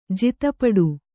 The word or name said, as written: ಜಿತಪಡು